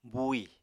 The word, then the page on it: buuì: apatani